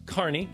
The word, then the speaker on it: Kearney: Anyway, out at the Jesse James Farm and Museum in Kearney. Did I say that right? – Yes, you did.